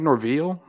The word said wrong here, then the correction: Norvell: Mike how do you say that? – Mike Mike Norvell.